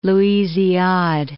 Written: Louisiade Archipelago

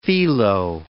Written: phyllo

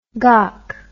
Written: gock

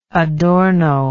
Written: Adorno

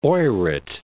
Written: Oyrot

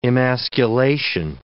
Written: emasculation

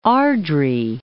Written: Airdrie

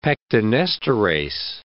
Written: pectinesterase

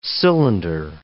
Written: cylinder

